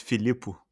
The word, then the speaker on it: Filippo: Do you like Filippo or Filippo? – I don't know, man. In Brazil, it's just Filippo, right? Okay. So in Italian, I think that's the way to pronounce it as well.